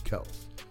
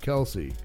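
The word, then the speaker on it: Kelce: That's actually how you say their name is Kels. It's not Kelsey. It's Kels. Is the correct pronunciation? – Because I pay attention.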